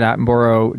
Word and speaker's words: Attenborough: I love, Yeah, you know, I'm always watching David Attenborough documentaries. – Yes. – Burrow. Sorry, how do you pronounce that? – Attenborough. That's how we would...